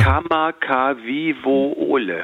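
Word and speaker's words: Kamagawiwole (Kamakawiwoʻole): Wie spricht man den aus? Kamagawiwiwowe? – Ulle? Würdest du mich jetzt so... Ich habe es jetzt gerade auch lustigerweise mir ja nochmal vorgeholt. Ja. Kamagawiwole. – Ole, genau so.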